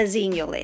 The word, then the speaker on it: Xennial: Let's start with what a Xennial is, spelt with an X, pronounced with a Z.